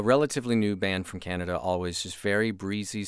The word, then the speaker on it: Alvvays: This is a band called Always, but they spell their name A-L-V-V-A-Y-S. So the VV stands in for the W. Yeah, I think they've had some people have been pronouncing it Always. It's Always.